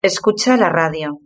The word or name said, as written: escucha la radio